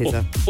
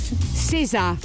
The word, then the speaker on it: SZA: It took me a long time to actually know how to pronounce her name. Because it's SZA is how she spells it. – Yeah, I didn't know it was pronounced SZA. – What you cut your hair with? – SZA.